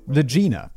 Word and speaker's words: Lagina: Lagina is Lagina. Oh, okay, so it's not pronounced like. Kate even makes the immediate joke that comes to my mind. Lagina is not pronounced. Lagina is not pronounced like you know what you know what rhymes with Lagina.